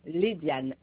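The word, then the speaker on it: Léadiane: C-H-E. C-A-D-I-C-H-E. Kadish. – D'accord, madame. Le prénom, s'il vous plaît? – Le prénom, c'est Léziane. Alors, L-E-A. L-E-A. – Oui. – D-I-A-N-E. Diane. Léziane. L-E-A-D-I-A-N-E.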